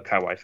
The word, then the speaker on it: kawaii: And it's got the kawaii face. Kiwi, however you pronounce it, face. – Kawaii face. That's right. It's like Hawaii.